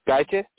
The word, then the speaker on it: Gaethje: Gaethje.